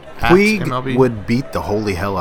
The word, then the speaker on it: Puig: Puig, is that how you pronounce it? Puig? – It's Puig.